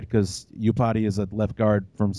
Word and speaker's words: Iupati: Speaking of the offensive line, you added Mike Iupati. You stole him from the that's how you pronounce it, right? Is it Iupati? – Iupati, yeah. – Iupati, there you go.